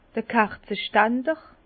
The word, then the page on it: d'r Kerzestander: Bas Rhin; Herrlisheim